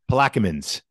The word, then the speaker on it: Plaquemines: Plaquemines.